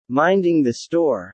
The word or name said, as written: minding the store